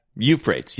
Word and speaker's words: Euphrates: Yeah, Euphrates.